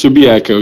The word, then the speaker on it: Subiaco: It's Subiaco. You said it right the first time.